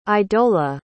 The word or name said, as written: idola